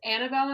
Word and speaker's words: Annabelle: Okay, so it's Annabelle, and my name is spelled like the doll, so A-N-N-A-B-E-L-L-E, and then the word and then Annalise's name, so A-N-N-E L-I-S-E.